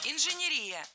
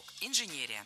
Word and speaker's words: инженерия: Инженерия – это неверно. Ударение в этом слове должно падать на третий слог – инженерия. В качестве шпаргалки предлагаю обратиться к однокоренному существительному «инженер». Инженер – инженерия. Преобразование слова на «ия» ударение не меняем.